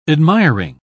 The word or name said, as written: admiring